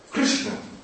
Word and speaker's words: Krishna: Krishna. Very difficult. This each sound. Ka. It is not re, not ru.